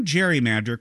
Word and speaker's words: gerrymander: But when the term gerrymander caught on, it wasn't with the hard G sound of Gerry's surname.